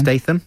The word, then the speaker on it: Statham: And I was with my friend Edgar, and Stratham was pushing... How do you say his name again? – Statham.